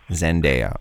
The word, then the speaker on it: Zendaya: Zendaya.